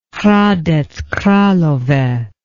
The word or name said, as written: hradec kralove